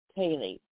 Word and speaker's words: ceilidh: Anyway, this song is by Tommy Scott, and it was posted by Celtic Note, and it's called Gospel Country Kaylee. That's Scott, yeah, because that is, or Irish, take your pick, they both do it. It's spelled C-E-I-L-I-D-H. And they spell things that way in Newfoundland, too.